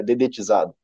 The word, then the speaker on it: dedetizado: Como é que é? Detetizado. É dedetizado, né?